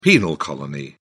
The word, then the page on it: penal colony: British English